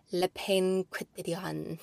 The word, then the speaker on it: Le Pain Quotidien: I love the ham and gruyere sandwich from, I can never pronounce it, Le Pain Quotidien. – That sounds right. – I say that right?